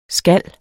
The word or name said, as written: skal